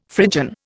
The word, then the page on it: Phrygian: Indian